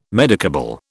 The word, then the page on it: medicable: British English